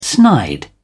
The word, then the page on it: snide: British English